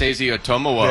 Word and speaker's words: Isaiah Otomowo: Isaiah Otomowo. – There we go. Thank you. – Big O. – Yes, that's what I think. I spelled it out phonetically because of you. Izezu Otemeo. Did I get close? No. – Not really.